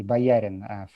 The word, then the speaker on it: Боярин: Да, мои бабушка с дедушкой говорили в русской версии Боярин фамилия. Да, сейчас в английской делают чаще ударение на первый слог.